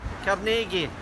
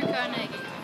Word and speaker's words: Carnegie: Carnegie. – Andrew Carnegie.